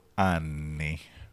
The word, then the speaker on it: anni: If you mispronounce the word anni, which means years, 100 anni, 100 years, but if you say it the way you said it, it means 100 buttholes. – Which is kind of the way the next eruption of Vesuvius might seem, so you never know. But yes, I have a problem with that. I need to hold those double letters.